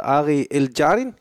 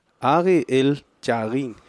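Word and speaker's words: Ari Eldjárn: Men grund til, at jeg også har det med, det er en komiker, der hedder Ari El Jardin. Og det skal ikke udtales sådan. Det lød mere mellemøstligt, en måde at udtale det. – Ari El Jarin øh, Pardon My Icelandic, som ligger på Netflix.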